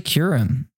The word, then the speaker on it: Kyurem: It's actually Kyurem, not Kyurem.